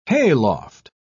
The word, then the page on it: hayloft: British English